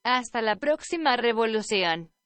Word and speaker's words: Hasta la Próxima Revolución: If you want a better pronunciation than I gave you, Hasta la Próxima Revolución.